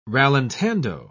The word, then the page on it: rallentando: British English